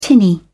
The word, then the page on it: tinny: British English